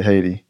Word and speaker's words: Haiti: which is the proper way to say Haiti.